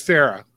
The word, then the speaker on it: Fera: It is Fera. – Yeah. – Oh, like Fera Fawcett. – Well, kind of, but the Italian version.